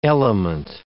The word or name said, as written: element